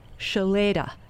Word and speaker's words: Shloeta: Spelled S-H-L-O-E-T-A and pronounced Shaleda.